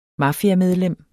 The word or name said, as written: mafiamedlem